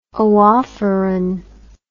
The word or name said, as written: oophoron